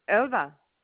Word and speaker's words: Elba: Elba.